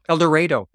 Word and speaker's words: El Dorado: El Dorado. It's a unique Arkansas pronunciation. Rhymes with tornado is how you remember it.